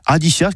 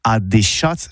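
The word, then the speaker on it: adishatz: Eh oui, adichats, que c'est prononcé au tabé, adichats, voire adéchats, surtout en Gascogne toulousaine.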